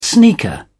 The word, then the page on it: sneaker: British English